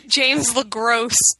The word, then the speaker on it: LeGros: So, patron saint from the show, apparently, James LeGros. – Okay, yes. Well, here's the thing. I've always said James LeGros because I assume that you don't pronounce the S.